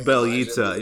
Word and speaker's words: Bjelica: Big on Bjelica, or however you say his name? – Bjelica, yeah.